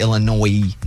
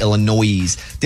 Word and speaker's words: Illinois: And then people that I know from Illinois be like, yo, there's no S. That'd be like saying your state of Arkansas. It's spelled like that, Arkansas. It's not spelled like Arkansas. So it's Illinois, not Illinois.